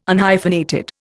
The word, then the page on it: Unhyphenated: Indian